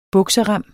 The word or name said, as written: bukserem